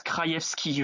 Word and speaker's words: Krajewski: Ça se prononce Krajewski, je crois, j'en suis quasiment sûr. Et effectivement, Krajewski, si on lit à la française, J-E-W, c'est intéressant.